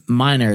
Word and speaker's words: Miner: But Minor, it's spelled M-I-N-E-R, like a coal miner, you know, in those ways.